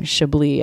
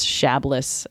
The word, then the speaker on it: Chablis: they were pronouncing chablis as shabless and then the next time i mentioned it i'd be like and why don't you look at this bottle of chablis